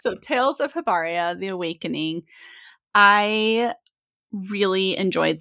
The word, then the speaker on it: Hibernia: So with Tales of Hibernia, I want to, am I saying that right? Hibernia? – Hibernia. – Hibernia. That's okay.